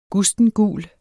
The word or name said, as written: gustengul